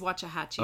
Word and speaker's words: Waxahachie: and he was born on this day in Waxahachie. – I think it's Waxahachie. – Okay. – Is how they say it down there.